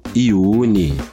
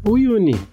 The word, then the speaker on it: Uyuni: Eu vi algumas, algumas pessoas falando Iune, Uini, mas é. – Eu nem pronunciei, porque eu nem sei pronunciar. Uyuni. – Pra mim tá lá, U-Y-U-N-I.